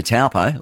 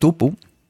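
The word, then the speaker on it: Taupō: They don't say taupo like we all do, you know. It's like tupu.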